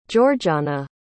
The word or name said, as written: jorjanna